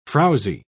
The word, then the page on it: frowsy: British English